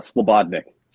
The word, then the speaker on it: Slobodnik: Slightly. It's Slobodnik. – Slobodnik, oh, with the bod, like body.